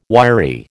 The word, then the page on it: wiry: American English